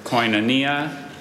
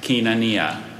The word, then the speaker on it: koinonia: Are there any alternate pronunciations? – There's koinonia and kinania.